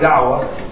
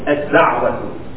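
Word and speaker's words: da'wah (الدعوة): The word is da'wah, and in Arabic it's spelled like this. Alif, Lam, Dal, Ain, Wow, ta'u marbuha, close ta. Alif lam dal ain wow ta'u marbuha. Ad-da'watu, ad-da'watu.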